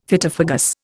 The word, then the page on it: Phytophagous: Indian